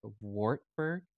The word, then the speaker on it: Wartburg: I gotta say, like, I also saw that recently you were accepted for a master's program at, and I'm going to mispronounce it, Wartburg Theological Seminary. – You got it perfect. That's perfect.